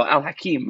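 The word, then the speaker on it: Al-Hakim: Now, uh, but something a, a instance of violence in the city is by Caliph uh, Al Hakim.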